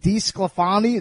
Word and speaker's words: DeSclafani: Is it DeSclafani? Is that how you pronounce his name? – Yeah, Anthony, yes.